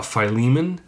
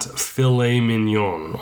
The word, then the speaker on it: Philemon: So the first one, Philemon, and that's how you pronounce it, Philemon, or Philemon, you could do it that way too, but it's not filet mignon, okay? It is not a steak.